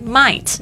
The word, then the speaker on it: might: Might 就是大概有可能 OK Might M-I-G-H-T 注意一下这个 G-H 不发音跟另外一个字很像是 Night 晚上 N-I-G-H-T G-H 这个这边的 I-G-H-T 呢它就是发 It 的声音就 G-H 不发音特别注意一下哦 Happen H-A-P-P-E-N